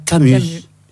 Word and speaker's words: Camus: no Espaço Albert Camus.